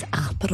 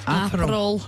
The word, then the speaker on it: Aperol: It's Aperol. – Oh. Is it what? – Aperol. – What did I say? Aperol. – Aperol.